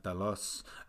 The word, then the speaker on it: Talos: And that for me is partly why I really like that scene with herself and Talos. Have I said that right? – Talos.